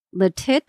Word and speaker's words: Lititz: in 1850 in the Sturgis Bakery in Lititz, Pennsylvania. I feel I'm saying that wrong, but it's L-I-T-I-T-Z. Lititz? – Checks out with me.